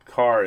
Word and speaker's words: Kahr: All right, moving right along, I also wanted to talk about another meeting that I had, which was with Carr Firearms, and that Carr is spelled K-A-H-R, and they were in the one of the booths.